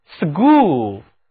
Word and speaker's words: school: 思也是我要引出的第一个词根因此把这个词根的中间塞进去一个字母 O 构成的 school 就是学习的场所学校注意在 school 当中字母 CH 音标是是清辅音但是呢，当我们把一个字母 S 放在它前面，这个 S 发 S 这样一个音的时候， CH 读音就要浊化成 G, G。来，跟我读，school school middle school 是中学，而 law school 是法学院。